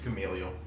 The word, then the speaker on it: Gamaliel: Like he was taught by Gamaliel, I believe is how you say his name. He was Gamaliel.